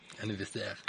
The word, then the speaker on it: anniversaire: Anniversaire. – Anniversaire. So it's French. – How do you pronounce it? Anniversaire. Anniversaire.